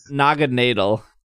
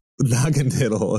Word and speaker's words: Naganadel: Naganadel. – Naganadel, okay. – Naganadel, because well, it's supposed to be Naga and then needle, but yeah, Naganadel.